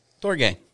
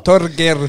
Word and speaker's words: Torgeir: I thought it was pronounced Torge. – You have to roll your R's, dude. Oh. – Torgeir.